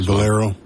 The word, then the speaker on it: Valero: Is it pronounced Valero or Vallejo? It's a Spanish thing. – Valero.